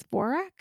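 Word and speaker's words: Dvořák: I think it's Dvorak.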